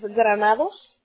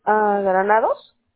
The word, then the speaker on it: Granados: Es Granados. – ¿Cómo se escribe? – Granados.